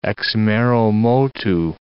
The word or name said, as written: ex mero motu